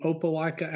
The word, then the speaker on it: Opelika: Well, we're coming from Opelika, Alabama. We like to say, if you struggle with how it's spelled, O-P-E-L-I-K-A, but Ash coined the phrase, hope you like a Opelika.